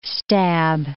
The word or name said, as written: stab